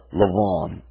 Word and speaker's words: Lavan: The name Rebekah, you would pronounce it in Hebrew, Rivka, and the name of her brother, Lavan.